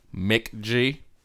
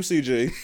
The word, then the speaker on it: McG: Mick G. – Is that how you pronounce that person's name? – Uh-huh. – I always called him MCG.